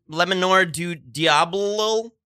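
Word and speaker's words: Le Manoir du Diable: Lemonor du Diablo? – That's a beautiful pronunciation.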